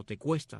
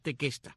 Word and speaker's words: Tequesta: Otros indios de esta área son los llamados tequesta o tecuesta, de acuerdo con los menciones, lo pronuncias al inglés o el español, pero es tequesta, tequesta con Q, T-E-Q-U-E-S-T-A, los tequesta, y los otros son los jaega.